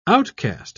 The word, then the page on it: outcaste: British English